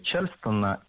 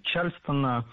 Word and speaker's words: Чарльстон: Чарльстон – это неверно. Правильное название – Чарльстон. Ударение в этом слове должно падать на второй слог. Это слово произошло от названия американского города. И город, и танец в русском языке имеют ударение на втором слоге – Чарльстон.